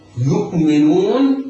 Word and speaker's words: يؤمنون: الذين يؤمنون، يؤمنون، يؤمن الذين، يؤمنون، ننطق ياء مضمومة بعدها همزة مرققة ساكنة ولا نقلقلها، يؤمنون ميم مكسورة نون مضمومة بعدها واو تمد مدا طبيعيا بمقدار حركتين بعدها النون المفتوح.